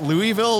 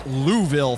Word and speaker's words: Louisville: Somebody barked Louisville a little bit too loud, and we got some locals who are like, it's Louisville, thanks. It's like, yeah, well, when you can pronounce Saskatchewan, come find me. – The E is silent. It's a silent E.